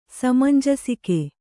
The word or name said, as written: ಸಮಂಜಸಿಕೆ